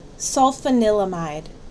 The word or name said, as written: Sulfanilamide